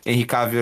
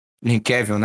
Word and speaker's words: Henry Cavill: do Henry Cavill ali pra... Henry Cavill, né? Falei errado.